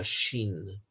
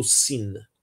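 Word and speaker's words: Shin (ש): Em hebraico, a letra Shin ela pode ser lida shin ou sin, dependendo de onde se coloca o ponto, à esquerda ou à direita. Shin ou sin, a mesma letra, mas ela tem duas pronúncias.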